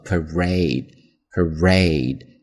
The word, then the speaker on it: parade: 它的重音在第二个音节上，所以呢读作 parade，parade 这么去读？后面的的会读的轻一些。